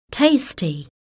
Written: tasty

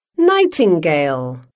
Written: nightingale

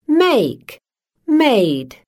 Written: make-made

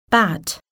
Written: bat

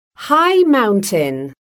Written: high mountain

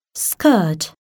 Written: skirt

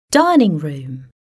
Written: dining room

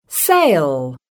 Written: sail

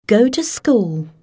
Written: go to school

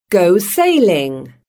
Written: go sailing

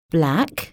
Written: black

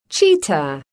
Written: cheetah

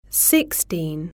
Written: sixteen

